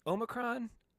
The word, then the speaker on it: Omicron: So if we're using the ancient Greek pronunciation, it would be something similar to Omicron, but no one says Omicron. So Omicron, Omicron, totally fine.